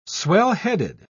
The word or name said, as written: swellheaded